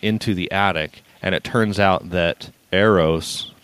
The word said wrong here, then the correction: Euros: did I say it right that time? – Euros. – Sister. – Euros. – Like Europe. – Okay, Europe, I got it.